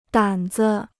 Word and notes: 胆子: dǎn zi

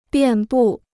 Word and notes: 遍布: biàn bù

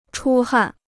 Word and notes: 出汗: chū hàn

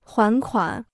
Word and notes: 还款: huán kuǎn

